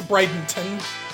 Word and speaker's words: Bradenton: And the correct pronunciation of the city that Cindy lives in is not Brandon, it's Bradenton.